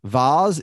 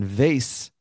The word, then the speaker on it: vase: Vase is the British pronunciation, and vase, the way I say it, is the American pronunciation, according to Google pronunciation.